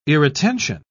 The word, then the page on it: irretention: British English